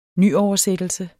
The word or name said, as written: nyoversættelse